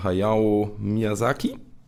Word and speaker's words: Hayao Miyazaki: Vielleicht der letzte Film von Hayao Miyazaki? – Ja.